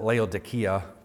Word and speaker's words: Laodicea: In the Greek, you would pronounce it Laodicea, by the way, but we just get used to saying it differently.